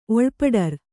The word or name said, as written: ಒಳ್ಪಡರ್